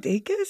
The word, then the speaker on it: Dacus: Is it Dacus? – Yeah, it's Dacus. – Oh, thank God. Like daytime kiss kiss.